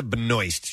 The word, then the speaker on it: Benoist: Melissa, do you pronounce her last name Benoist? – I do. – Supergirl? – Yes. – Okay. Melissa Benoist.